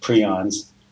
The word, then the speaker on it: prions: It's spelled prions, but it's actually pronounced prions, the way the inventor of the term calls it.